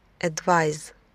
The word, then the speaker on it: advise: А глагол advise, советовать, давать советы, пишется с буквой S и произносится как wise или realize, advise.